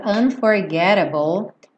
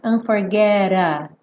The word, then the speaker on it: unforgettable: Que é, por exemplo, essa típica redução do T para rrr no inglês americano. Nat King Cole, um cantor americano, e a grande parte das regravações dessa canção foram feitas por cantores americanos ou que pronunciavam em inglês à maneira americana. Então nós temos unforgettable, igual na palavra agora, unforgettable.